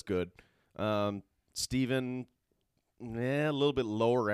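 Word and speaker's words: Stephen: "Kiss and Tell" and Steven, uh, or Stefan, depending on how you want to pronounce yeah, it. Steven. She says Steven in the song.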